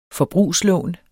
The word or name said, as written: forbrugslån